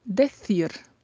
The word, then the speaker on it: decir: Decir. Decir é o verbo dizer em espanhol. Atenção à pronúncia, porque o C tem aquele som com a língua entre os dentes, decir.